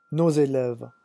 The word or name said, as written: nos élèves